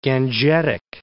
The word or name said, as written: Gangetic